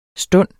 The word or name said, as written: stund